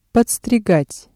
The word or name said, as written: подстригать